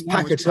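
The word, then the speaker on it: Paquetá: Accent on the final A. – I'm tempted to try and find it's Paqueta. Right.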